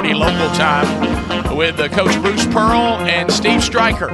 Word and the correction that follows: Stricker: He'll be doing Stricker. That'll be happening today. It's spelled Stricker. Stricker.